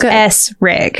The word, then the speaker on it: Esring: It's spelled phonetically. Like, it's spelled how you say it. Like, it's S-Rig.